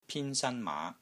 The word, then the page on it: PIN1 SAN1 MAA5: Cantonese